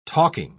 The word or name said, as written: talking